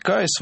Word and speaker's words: Kyuss: Называлась Caius. Вообще сложно, это нужно по буквам диктовать. K-Y-U-S-S. Вот так оно произносится.